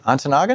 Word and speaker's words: Ontonagon: Ontonagon? Am I saying that right? – Yes, you are.